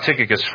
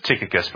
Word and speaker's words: Tychicus: Let's talk about Tychicus first. So we should probably talk about the pronunciation of this name. For my entire life, I've always pronounced it, this name is Tychicus.